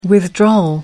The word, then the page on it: withdrawal: American English